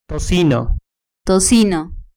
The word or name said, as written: tocino